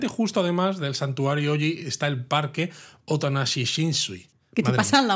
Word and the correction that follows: Otonashi Shinsui: sé si lo he dicho bien porque el nombre es se Otonashi Shinsui.